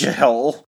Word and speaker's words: Jihl: J-I-H-L, Jill? – Yep. – So make sure you pronounce the H. – Jill.